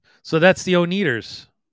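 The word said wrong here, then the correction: Oneders: Did I mispronounce that? Oh, the Wonders.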